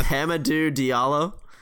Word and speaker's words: Hamidou Diallo: Hamidou Diallo.